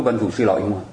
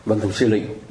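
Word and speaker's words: Văn Thù Sư Lị: mình cứ văn thủ sư lợi, mà ai nói văn thủ sư lị thì mình nói người ta đọc sai, mà trong khi người ta đọc đúng, văn thủ sư lị mới là đúng.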